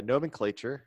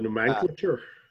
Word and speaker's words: nomenclature: Another fun fact about the nomenclature, the naming scheme for hurricanes. – The nomenclature. – Yes, if you want to pronounce it like that, you weirdo. – Wrong emphasis on the wrong syllable.